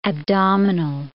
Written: abdominal

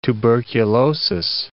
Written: tuberculosis

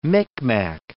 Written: Micmac

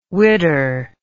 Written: widder

